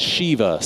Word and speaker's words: Shiva: And in the Jewish culture, there's this mourning ritual called Shiva.